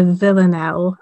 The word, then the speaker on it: villanelle: Yeah, so firstly, the form was quite interesting because it's a villanelle.